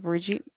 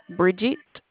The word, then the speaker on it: Bridget: My name is Bridget. – Virgie? – Bridget.